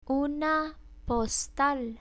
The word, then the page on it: una postal: Spanish